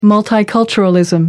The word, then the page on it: multiculturalism: American English